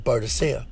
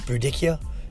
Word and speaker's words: Boudicca: I look at the story of Boadicea. That's the Nicene queen in England when Rome invaded. If you're not familiar with, I think it's pronounced Boudicca is the proper pronunciation.